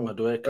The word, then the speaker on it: Madueke: It's already insane because not only have you secured Ornstein Dagger or Ornstein Bomb as they call it, um, how do you say his name? Modieki? I don't know. Madueke.